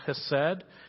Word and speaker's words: chesed: It's called chesed. It's spelled C-H-E-S-E-D, C-H-E-S-E-D, and it's chesed, and it's forgiveness that comes from God's character.